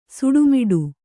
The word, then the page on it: ಸುಡುಮಿಡು: suḍumiḍu